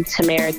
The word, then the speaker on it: Tamera: King_ and my music is also located on all music platforms, so like Spotify, Apple Music, and it's also my name, Tamera King, just without the underscore.